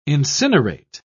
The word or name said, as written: incinerate